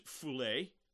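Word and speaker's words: phule (φυλή): The Greek word here translated as tribes is the word pronounced phule.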